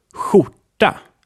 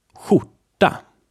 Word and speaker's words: skjorta: It is pronounced like sh, which means a lot of air is pressed against the roof of your mouth. And check out the accompanying text so that you can see the different spellings of the following examples. Let's hear them. Skjorta, which means shirt. Skjorta. This one is spelled with S, K, and J.